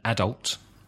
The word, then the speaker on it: adult: Another word which changes its stress is A-D-U-L-T. In British English, adult. I am an adult. – And American, adult. Stressing the second syllable. – Mm-hmm. An interesting word which usually makes me laugh is H-E-R-B.